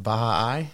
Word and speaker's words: Baha'i: Do you know how to pronounce that, by the way? Did you figure that out? – Baha'i? – Is that right? Okay. That's kind of how it sounds to me. – That's how I've always heard it pronounced.